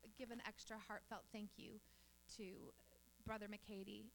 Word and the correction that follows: McAtee: am I saying it right? McAtee, and your wife.